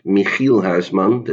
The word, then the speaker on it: Michiel Huisman: There is a Dutch actor in it, Michiel Huisman, that's how you should pronounce it, who also played Cal in Orphan Black and as you know, Orphan Black is a very important series for me.